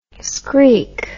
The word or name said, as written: screek